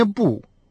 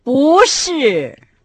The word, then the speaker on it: 不: Обратите внимание на то, что связка «ши» произносится с четвертым тоном, так же, как и отрицание «бу». Поэтому перед связкой это отрицание по общему правилу получает второй тон. – «Буши!